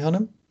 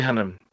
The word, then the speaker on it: Hunnam: Ben Hannum. What did you say his name was? – Charlie Hunnam. – Charlie Hunnam. – Yeah.